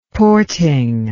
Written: porting

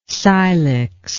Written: cylix

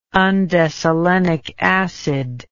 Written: undecylenic acid

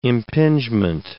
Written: impingement

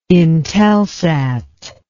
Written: intelsat

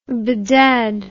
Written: bedad

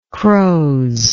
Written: croze

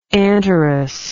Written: Anterus

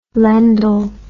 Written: Lendl

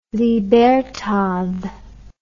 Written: libertad

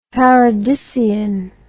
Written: paradisean

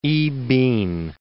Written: I-pin

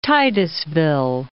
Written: Titusville

